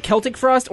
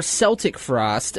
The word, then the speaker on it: Celtic Frost: Depending on how old you are, either you pronounce it Celtic Frost or Celtic Frost.